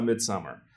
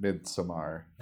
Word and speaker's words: Midsommar: But I finally saw Midsommar, and I really enjoyed it. – Is it Midsommar or is it Midsommar? – They say Midsommar in the movie, but it is spelled Midsommar.